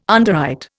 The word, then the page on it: underwrite: Indian